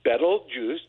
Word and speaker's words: Betelgeuse: And Betelgeuse, he says, Betelgeuse, just like that.